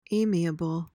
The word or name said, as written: amiable